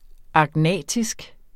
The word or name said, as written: agnatisk